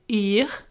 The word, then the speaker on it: Yr: Le tout premier conseil que je vous donnerais, ce serait de télécharger l'application météo norvégienne qui s'appelle IR, donc ça s'écrit YR.